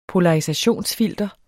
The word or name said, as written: polarisationsfilter